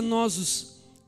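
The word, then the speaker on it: nosos (νόσος): Então, a primeira palavra, a primeira categoria de doenças que Jesus curou e ainda cura, ela vem de uma palavra pequenininha, que se pronuncia nosos, que é encontrada em Mateus 4, que foi somente traduzida pela palavra enfermidade ou doença em algumas versões.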